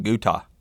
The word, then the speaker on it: Goethe: This is from Goethe. That's how you say it, but it's spelled G-O-E-T-H-E.